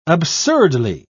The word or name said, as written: absurdly